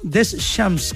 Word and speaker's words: Deschamps: Hoy habló Didier Deschamps, Deschamps, como sería la pronunciación correcta,